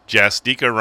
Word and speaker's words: Justica: Justica rhymes with Eureka, so I'm saying it right.